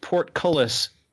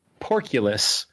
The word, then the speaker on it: portcullis: And it became kind of a running joke for a while, several years ago, that he pronounced Portcullis Porculus. And so, like, I've got this image in my mind of, like, oh, what's the name of that car?